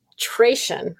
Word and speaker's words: disenculturation: i'm probably going to say it wrong disincul- Disenculturation. – Disenculturation, yes. Okay, disenculturation. Okay, thank you for helping me pronounce that.